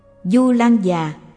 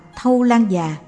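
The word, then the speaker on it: Thâu Lan Giá: Du Lan Già cũng đọc là Thâu Lan Già, dịch nghĩa là tác đại chướng thiện đạo.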